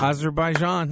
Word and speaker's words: Azerbaijan: Figured out how to say it, dude. – Azerbaijan, that's right.